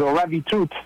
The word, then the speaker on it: ravitoto: And then the other one is the ravitout, which is again, it's written ravitoto, so R-A-V-I-T-O-T-O.